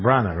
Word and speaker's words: Branagh: Is that you pronounce it? Branagh, right? Not Brian.